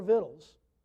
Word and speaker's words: victuals: Okay, that word, that's not just an ancient spelling. We actually still spell it like victuals today, but it's pronounced all their victuals, and went their way.